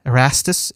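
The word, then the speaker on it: Ersatz: This uh, I'm, probably not saying it right, but uh, uh It's E uh, Erastus. It's German. It's E R S A T Z.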